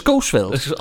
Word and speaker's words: Coesfeld: Coesfeld kann man so geil holländisch aussprechen. Ne? Das Coesfeld. – Das Coesfeld.